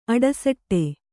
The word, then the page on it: ಅಡಿಸಡಿ: aḍisaḍi